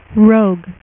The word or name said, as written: Rogue